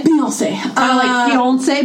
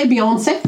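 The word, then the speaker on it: Beyoncé: Pronounce it Beyonce. – Beyonce. – Beyonce. – Yeah.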